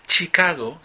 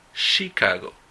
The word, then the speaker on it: Chicago: Cuidado con la pronunciación de Chicago. No es Chicago, sino es Chicago. La CH en esta palabra se pronuncia como sh.